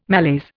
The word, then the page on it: malaise: Indian